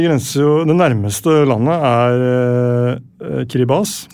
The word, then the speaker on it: Kiribati: Ja, det heter jo ikke Kiribati. – Nei, selv om det mange som leser vil jo anta det selvfølgelig. – Ja, det heter Kiribati. T-I uttales vanligvis ikke S.